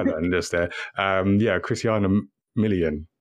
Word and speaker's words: Milian: Saw this this film Resort to Love, which is basically it's like a rom-com with Christiana Milian. Wait, I feel like I've just butchered her name. – Christiana. – Wait, Milian. – Did I say Milian? – You said Milan.